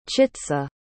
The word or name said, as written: chitsa